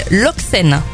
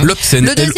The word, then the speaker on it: Loecsen: Alors, le premier, ça s'appelle Loxen. L-O-E-C-S-E-N.